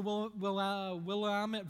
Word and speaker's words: Willamette: Now, if you've been to Sonoma or the Willamette Valley, that's how you say it, yes, you would see something like this in your mind, maybe.